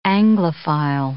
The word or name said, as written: Anglophile